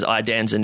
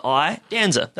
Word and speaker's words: Iadanza: Yeah, I just say it like I, like as in your E-Y-E, your Iadanza.